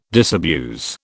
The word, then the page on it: disabuse: British English